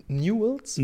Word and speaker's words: Newell's: Newell's? – Newell's Old Boys. – Wie schreibt man Newell? – N-E-W-E-L-L Apostroph S Old Boys.